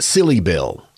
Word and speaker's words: Silibil: And it was also almost pronounced Silly Bill. It was a combination of syllable and Silly Bill. – So it was Silly Bill.